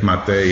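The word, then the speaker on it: Gabor Maté: So there's a guy named Gabor Mate. I'm not going to say his name right, but it's G-A-B-O-R-M-A-T-E, but the E has an accent over it, so I don't know.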